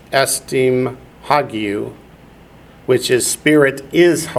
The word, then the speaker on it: hagiou: That in Greek is pneumatos hagiou, which is Holy Spirit, holy there being an adjective and spirit being a noun.